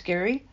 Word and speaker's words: Gerry: Check out all of Gary, G-E-R-R-Y, but pronounce Gary, all of his writings.